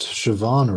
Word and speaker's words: Siobhan: They got a Scottish actress, Siobhan Redman, I believe her name is. They got her to... – at least you pronounced Siobhan right. Most people look at that and they don't know how to pronounce it.